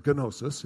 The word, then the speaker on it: gnosis: And the word has a silent G on the front, so it looks like gnosis if you speak English.